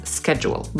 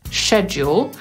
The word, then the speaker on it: schedule: To jest wymowa amerykańska, class schedule. Brytyjczyk powiedziałby raczej class schedule i o słowie schedule czy schedule też już nagrałam odcinek i link do niego też jest w opisie.